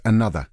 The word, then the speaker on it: Anotr: Going, despite the fact that the act is spelled A-N-O-T-R, it's pronounced another.